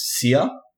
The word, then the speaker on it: Cere: I think her name is Sia. – Mm. Oh, but we saw it in the trailer. – Yeah, but it's spelled like C-E-R-E.